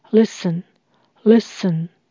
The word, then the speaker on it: listen: 请留意这边的 t 是不发音的。 Listen，listen。